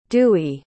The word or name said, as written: duy